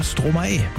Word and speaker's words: Stromae: Ich weiß jetzt, wie man den ausspricht. Hab den mir letzte Woche geschrieben. Hab gesagt, Mensch, der hat doch einfach Maestro umgedreht, die Silben. Und deswegen heißt der Stromae.